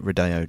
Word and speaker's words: Rodeo: I wonder what the deal is with the Rodeo Drive. – I think it's Rodeo Drive is how it's more typically pronounced.